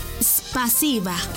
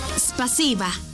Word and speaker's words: спасибо: En ruso, gracias se dice spasiba. ¿Cómo? Spasiba. Una vez más, spasiba. Y se escribe spasiba. S-P-A-S-I-B-A.